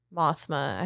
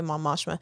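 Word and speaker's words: Mon Mothma: So I had him on Mothma. I have to remember the right way to say it so I don't say Mom Moshma.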